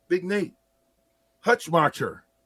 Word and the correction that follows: Hutmaker: No, Nash Hutmaker. Technically, that's actually how you say it. We asked his parents. Nash. We saw them at Tipsy Tina's last year for a pregame. It's actually pronounced Hutmaker.